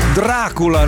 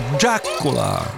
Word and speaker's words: Dracula: Dracula, noi lo pronunciamo all'italiana, ma loro lo pronunciano con un forte accento inglese o americano e dice Dracula.